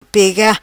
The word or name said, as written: Pega